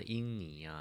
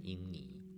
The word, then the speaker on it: 婴儿: 专气致柔，能婴泥乎？这里提到的婴泥啊，其实就是我们日常常说的婴儿啊。在古音里面呢，它经常会被念成婴泥。